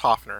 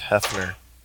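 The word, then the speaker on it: Höfner: Pronounced Hefner. Because you put an umlaut over an O, and it just becomes like kind of like a schwa sound.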